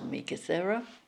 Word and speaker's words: Meekatharra: And then we went the 9 years to Meekatharra, which is spelled M-E-E-K-A-R-R-A, and it rhymes with Yarra, and had the 9 years there.